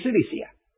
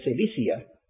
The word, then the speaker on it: Cilicia: Yet, there may have been a reason why he said yet, because I think I mentioned to you that Cilicia, which was pronounced with a K, not written with a soft C, there were three K's that were said to be terrible in their wickedness.